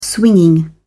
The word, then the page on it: swinging: British English